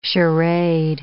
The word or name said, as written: charade